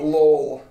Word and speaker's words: Lowell: How do you say it? – Lowell. Lowell. Lowell, Indiana.